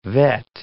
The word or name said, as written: vet